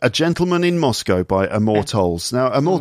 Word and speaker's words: Amor Towles: It's A Gentleman in Moscow by, I'm not quite sure how to pronounce his name. Amor Towles? – That's right. Towles. Amor Towles.